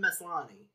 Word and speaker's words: Maslany: Yeah, Tatiana Maslany.